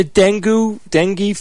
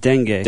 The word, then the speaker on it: dengue: I mean, the dengue fever, Steve? – I think it's pronounced dengue.